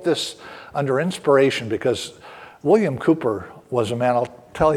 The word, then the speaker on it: Cowper: It's pronounced Cooper. It looks like Cowper, but in 1774, he wrote this hymn.